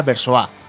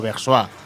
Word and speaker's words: Versoix: Es, realmente está tomado de una ciudad suiza, ¿no? Que se diría Versois o Versois.